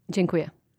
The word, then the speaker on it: Dziękuję: Dziękuję. – Dziękuję.